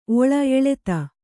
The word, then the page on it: ಎಳ್ತು: eḷtu